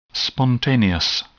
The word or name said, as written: spontaneous